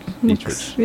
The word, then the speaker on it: Dietrich: Dietrich.